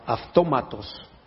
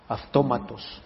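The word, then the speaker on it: aftómatos (αὐτόματος): Nunca se las he dicho, pero es la palabra aftómatos. Aftómatos es de donde viene automático. Aftómatos, incluso si la del griego la transliteras al castellano, casi se escribe automatos, pero se pronuncia aftómatos.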